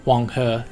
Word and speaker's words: Huanghe: the yellow or Huanghe, as it's pronounced in China.